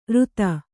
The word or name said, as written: ರುತ